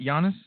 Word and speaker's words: Giannis: So the Giannis and am I saying that right? Giannis? – Yes.